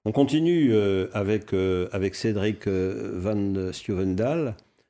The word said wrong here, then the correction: Van Styvendael: J'espère que je prononce correctement. – C'est plus simple, c'est Van Stevendel. Van Stevendel. Voilà.